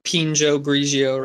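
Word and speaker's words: Pinot Grigio: Dude, I'm drinking a cup of Pinjo Grigio right now. – I think you absolutely nailed that. That's exactly how it's said.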